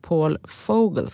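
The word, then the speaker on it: Paul Vogel: finanschef Paul Fogel, ska man faktiskt uttala det, också får lämna.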